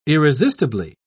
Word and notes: irresistibly: British English

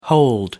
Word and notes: hold forth: American English

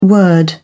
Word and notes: word: British English

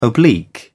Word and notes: oblique: British English

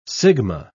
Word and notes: sigma: British English

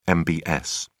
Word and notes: mbs: British English

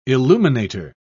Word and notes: illuminator: British English

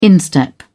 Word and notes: instep: British English

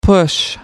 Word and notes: push on: American English

